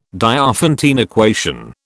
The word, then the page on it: Diophantine equation: British English